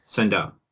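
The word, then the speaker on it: Sendou: Ron Sendo. – Ron Sendo. Okay, Ron Sendo. – It's got a U at the end. Sendo.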